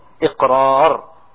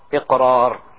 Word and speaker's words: iqrar: In Arabic we call it iqrar. Iqrar. Okay? Hamza, qaf, ra, alif, ra. Iqrar. Okay?